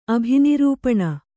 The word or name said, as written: ಅಭಿನಿರೂಪಣ